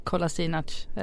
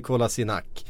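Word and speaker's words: Kolašinac: Men han uttalar ju tydligen fel själv. Okay. Det är det som är problemet. – Men visst är det så, ja. – Eftersom han är väl född och uppvuxen i Tyskland. – Ja. – Så att han har liksom ett tyskt uttal. Så att alla bosnier som hör det, de tycker att vad är det där? Det där stämmer inte alls. Men han säger Kolasinac, fast det ska vara Kolasinac, tror jag, eller sånt där, egentligen.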